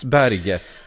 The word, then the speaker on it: Berge: Jo, det är norskt, och det uttalas Berge. Det är bara att han av någon anledning försöker få någon slags internationell touch på det.